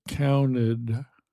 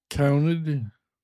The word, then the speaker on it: counted: When I say it in my normal speech, counted, counted, counted. The T sound is gone.